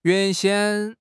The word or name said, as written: 原先